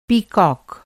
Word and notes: पीकॉक: Hindi

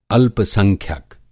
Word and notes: संख्यक: Hindi

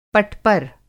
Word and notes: पटपर: Hindi

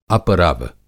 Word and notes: अपरव: Hindi

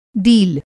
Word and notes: डील: Hindi

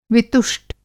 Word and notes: वितुष्ट: Hindi

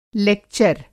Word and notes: लेक्चर: लेक्चर - لیکچر

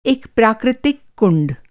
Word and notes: कुंड: کنڈ

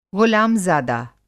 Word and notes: ग़ुलामज़ादा: غلام زادہ

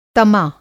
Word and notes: तमा: Hindi

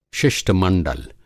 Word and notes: शिष्ट-मंडल: शिष्टमंडल

